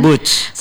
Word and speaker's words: boots: Atau bot sih bacanya. – Boots kan? – Boots.